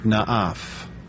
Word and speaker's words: naaph: The principal word for adultery, translated from the Hebrew, is the Hebrew word na'af. Na'af is spelled N-A-A-P-H.